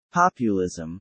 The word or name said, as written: populism